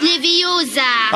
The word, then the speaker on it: Leviosa: Und übrigens hast du die falsche Betonung. Es heißt Leviosa.